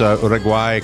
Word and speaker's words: Uruguay: Did I pronounce Uruguay correctly there? – Yes, Uruguay. You said it very nicely.